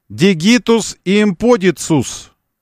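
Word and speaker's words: digitus impudicus: Значит, бесстыдный палец, назвав его дигитус имподикус. К не было у них, С было. Поэтому дигитус имподицус. Ну, не было у них произношения на К, К, К. Было С, поэтому Цезарь, а не кесарь, Цезарь.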